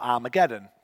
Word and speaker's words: Armageddon: Now, what's interesting, ha wasn't pronounced with a H back then, it was pronounced ah. So it would have actually been Armageddo or Armageddon.